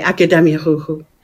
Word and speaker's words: Akademia Ruchu: It was Akademia Ruchu. Is that the correct pronunciation? – Czy wymawiam to poprawnie? – Okay, Akademia Ruchu.